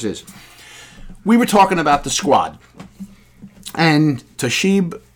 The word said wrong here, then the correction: Tlaib: am I saying her name right? Tlaib? Rashida Tlaib,